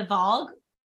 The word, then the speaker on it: Valg: V-A-L-G, the Vogue.